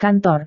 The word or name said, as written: kantor